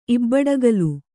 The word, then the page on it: ಇಬ್ಬಡಗಲು: kannada